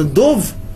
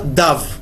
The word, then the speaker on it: дов: Да, мы, э, мы зачитали «Дамья ледов», хотя и написано «дов», но хотя и читается «дов», но написано «дав».